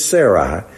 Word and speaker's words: Sarai: Right now, I think the way you say her name is Sarai.